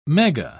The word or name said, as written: mega